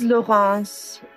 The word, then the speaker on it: Laurence: My name is Laurence. That's spelled like Lawrence, L-A-U-R-E-N-C-E.